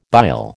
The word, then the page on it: bile: American English